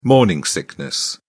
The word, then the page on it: morning sickness: British English